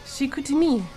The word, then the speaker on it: Chicoutimi: So you are going to turn those reviewing skills to the dragonfly of, pronounce it for me, Shikutimi. – Nice.